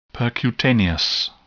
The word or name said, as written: percutaneous